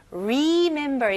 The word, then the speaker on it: remember: 네, 여기서는 스펠링은 RE이지만 발음은 RI 느낌으로 시작하면 좋아요. 그래서 remember 이렇게 힘 주실 필요 없고요. remember, remember. Remember. 네, 강세가 어디에 들어가죠? Remember. 네, 두 번째, 딱 중간에, mem 부분에 넣으시면 됩니다.